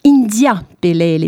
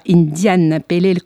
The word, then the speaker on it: India Pale Ale: On parle de révolution d'IPA bien sûr, particulièrement comment on va aborder ces trois lettres de cet acronyme qui signifie donc India Pelel et pas Indian Pelel comme on peut le lire souvent. On l'expliquera aussi. Bref, c'est un mot anglais qui se prononce donc à l'anglaise, IPA, India Pale Ale.